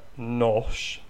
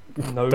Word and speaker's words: Knos: So it's spelled like K-N-O-S, which would be something like nosh, maybe? – Nose? Don't get that wrong.